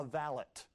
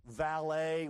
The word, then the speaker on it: valet: You know, it was kind of like what the British would call a valet, okay? Valet, we use the French pronunciation. They don't use the French pronunciation in Britain. No. That's a valet, okay, in Britain.